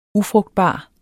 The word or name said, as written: UFRUGTBARE